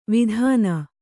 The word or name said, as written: ವೇದನ